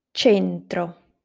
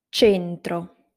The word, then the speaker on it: centro: cemento, centro, cesto. I'll repeat them again. Cemento, centro, cesto. To sum up, if the letter C is followed by I or E, it is pronounced as a soft sound.